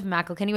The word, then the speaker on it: McIlkenny: Yes, guys, come check me out at Liv McElkinney, which I'm going to spell my last name since nobody knows how to spell it.